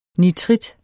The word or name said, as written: nitrit